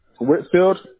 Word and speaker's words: Whitfield: Whitfield, W-H-I-T-F-I-E-L-D.